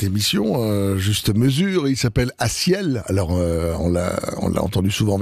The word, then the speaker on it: A6EL: Je vais vous appeler Aciel si vous le connaissez pas encore et si vous le cherchez, parce que ça s'écrit un peu spécialement A6EL, à ciel, pour cette chanson qui s'appelle excusez-moi, Soleil.